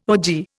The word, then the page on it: ogee: Indian